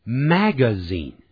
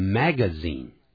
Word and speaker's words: magazine: 那么这个 is 念法可不要念成 is Tina is reading a book，正在看杂志呢，有两种发音，可以说成 magazine，像我们待会可以听到 Bruce 会念成 magazine。而另外呢，这位女的老师 J 会念成 magazine magazine magazine magazine，两个念法都对。